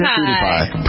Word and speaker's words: PewDiePie: How do you pronounce PewDiePie. – It? – PewDiePie. – PewDiePie, but it's spelled like PewDiePie. – It's spelled P-E-W. – P-E-W-D-I-E. P-I-E. – Like the Pew Institute.